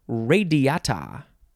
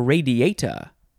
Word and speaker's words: radiata: Corona radiata, or radiata. How do you say this one? I think either one of those is fine.